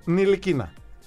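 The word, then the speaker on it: Ntilikina: Ντιλικινά. – Όχι. – Εσύ, μισό λεπτό. – Όχι. – Συμφωνούμε ότι είναι ντι. – Όχι. – Όχι. – Νι. Το τάφ δεν προφέρεται. Στα γαλλικά.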